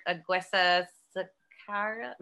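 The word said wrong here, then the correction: Aguirre-Sacasa: No, I said that wrong. Aguera Sacasa.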